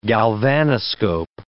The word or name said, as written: galvanoscope